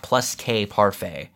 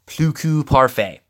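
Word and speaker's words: Plus Que Parfait: It's not Plus K Parfait. I believe it's Pluku Parfait.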